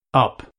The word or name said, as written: up